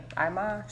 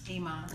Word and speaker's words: Iman: Iman Shumpert. – Iman. – Iman. I always say Iman, but somebody told me it was Iman today. I was like, that's not right.